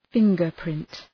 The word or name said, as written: fingerprint